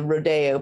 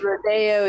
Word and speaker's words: rodeo: Rodeo is basically the Spanish pronunciation of the word. So I imagine as it's transformed, uh, and people have gone away from the true form, it's uh, rodeo.